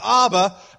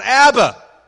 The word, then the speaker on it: Abba: This is Abba, pronounced Abba, not Abba.